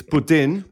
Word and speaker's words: poutine: Yes, but it's French. – Poutine. – It's from Quebec, and they don't pronounce it that way. – Poutine. – They say poutine. – Poutine. – Don't say it like Americans. Say it like the French Canadian do in Quebec. It's poutine.